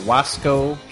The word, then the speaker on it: Wasco: I think it's Wasco. – W-A-S-C-O. Wasco, California.